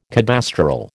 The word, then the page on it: cadastral: American English